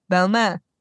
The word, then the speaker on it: Balmain: And we actually had a really great conversation with Olivier Rostang, who is the creative director of Balmain. Was that pronunciation good, Chloe? – Perfect.